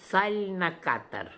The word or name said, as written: Zalnakatar